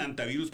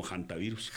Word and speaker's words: hantavirus: Bueno, unos dicen antivirus porque la H es muda. Pero sí, en medicina, sí. Pues nosotros la pronunciamos siempre es como hantavirus. – Hantavirus. – Porque acuérdense que incluso, la H es muda. Pero es hanta.